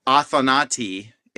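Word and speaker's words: athanatoi: You know, Athanati is how you pronounce it in modern Greek.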